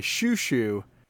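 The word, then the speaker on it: Xiu Xiu: And this is a song by Shushu. That is spelled XIUXIU.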